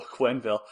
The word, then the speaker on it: Quenneville: Joel Quenneville.